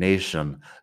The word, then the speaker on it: nation: Lo único es la pronunciación, nation. La T normalmente suena a T, pero en estas palabras es como si fuera sh, un poco. Nation. Bueno, un poco no, es sh, nation.